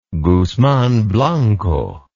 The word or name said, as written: guzman blanco